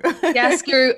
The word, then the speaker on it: Chiaroscuro: Ki- Chiaroscuro. – Chiaroscuro at the Bush, and also Nala at uh, Disney's The Lion King.